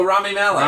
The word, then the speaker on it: Rami Malek: Oh, Rami Malek.